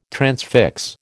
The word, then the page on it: transfix: American English